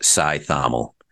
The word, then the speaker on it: Cajthaml: So my full last name is pronounced Cajthaml.